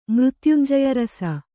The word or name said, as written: ಮೃತ್ಯುಂಜಯರಸ